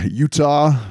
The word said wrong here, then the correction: Uatu: The Watcher's name is spelled U-A-T-U. I'm not sure how it's pronounced. – Uatu. – Uatu, there you go. All right. – Uatu. – Uatu, all right. Anybody who's listened to this show knows I'm horrible at pronunciation. – Gotta get that U and A sound in there.